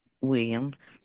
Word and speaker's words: Williams: Williams, WILLIAMS.